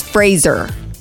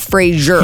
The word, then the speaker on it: Fraser: Brendan Fraser is Fraser. – No, yes, it is. – It is. – It rhymes with razor. Brendan Fraser, not Fraser.